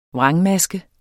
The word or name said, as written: vrangmaske